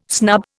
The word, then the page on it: snub: Indian